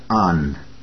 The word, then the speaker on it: an: Это очень немного, всего лишь 2 буквы, из которых состоит маленькое словечко, которое на немецком языке читается как «ан», «а-н», «ан».